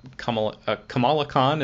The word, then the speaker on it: Kamala Khan: And I mean, especially the what you mentioned as Kamala Khan, is that how you pronounce the character's name? – Yes.